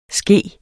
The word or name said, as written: ske